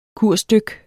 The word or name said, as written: kursdyk